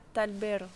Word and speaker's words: Talvera: L'association s'appelle Cordae la Talbera. Ça s'écrit Talvera.